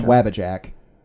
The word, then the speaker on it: Wabbajack: Like, you can pronounce it as a long A, and knowing Skyrim, it probably was. – Wabbajack.